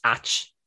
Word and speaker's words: ait: Like the minor thing I always teach to kids when I'm doing Irish with them, there's the word AIT is pronounced ach in Irish, and it means strange.